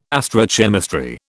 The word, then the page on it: astrochemistry: British English